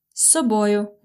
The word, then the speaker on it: з собою: Or з собою, будь ласка. You see, this з собою is two words. It's з собою. But when you pronounce it fast, you really hear just long с.